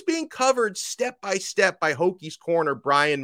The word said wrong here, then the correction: Breon: Murray, and he still managed, Breon Murray, I think is the way you pronounce that.